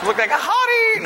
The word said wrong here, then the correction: haughty: Hottie. Did I pronounce it wrong? – No. You did. – Hottie. – Haughty. – Haughty. – Haughty. – Just a little bit more uppity.